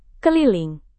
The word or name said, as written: keliling